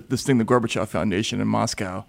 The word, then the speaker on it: Gorbachev: Gorbachev, is that how you say it? – Yes, I actually encountered Gorbachev twice,